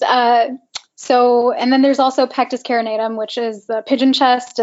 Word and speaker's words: Carinatum: You can treat this with a Nuspar, and then the pectus carnitatum. Pectus carnitum. – Carnitum. – How do you say it? – Carinatum.